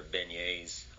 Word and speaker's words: beignet: Yeah, there's famous spots down in New Orleans that they're known for their beignets. I don't even know if I'm saying that right. – Yeah. Yeah, you're saying it right.